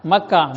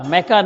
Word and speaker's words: مکہ: اصل جو سپیلنگ ہے MAKKAH مکہ، کیا ہے مکہ، میکہ نہیں ہے۔ یہ بات سمجھ میں آ گئی؟